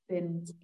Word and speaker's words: Zen: Zen, genau. Z wird wie so ein S ausgesprochen. Ja, also Zen.